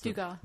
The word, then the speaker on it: Dugat: Is Dugat, that's how you pronounce Dugat. – It? Dugat. – Yeah, no T.